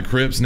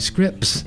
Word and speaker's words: Scripps: There's an S at the beginning and the end, but no T, oddly enough. It sounds like Scripps.